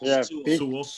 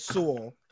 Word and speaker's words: Sewell: Is that how you say it? Right. – Sewell. Be careful. – I think it's Sewell.